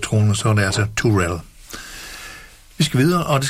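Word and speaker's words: Terrell: Tammy var korrekt nok, men efternavnene, det udtales faktisk Turell. Altså Tammy Turell hedder hun, selvom det staves T-R-R-I-L-L.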